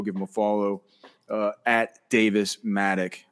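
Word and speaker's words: Davis Mattek: Okay, he's Davis Matic again.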